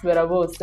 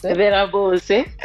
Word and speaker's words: Berabose: So it's pronounced Berabose. – Berabose. Oh, I love that.